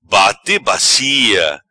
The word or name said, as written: Bate bacia